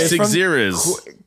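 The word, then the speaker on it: Qixeriz: Right. Sixiris. – Sixiris. – Yeah, it's Q-I-X-E-R-I-Z. I'm sorry, I completely butchered your handle.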